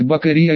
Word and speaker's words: Бакрия: Во-первых, оно произносится как Бакрия легко, и происходит от японского имени Бакурю.